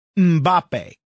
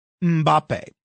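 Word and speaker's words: Mbappé: I don't know anything about soccer in France, but this French football star, I believe his name is pronounced Mbappe. Mbappe.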